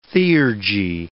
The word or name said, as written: theurgy